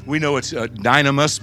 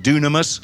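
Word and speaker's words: dunamis (δύναμις): We know it's dynamis, but it's actually, well, we pronounce it dunamis.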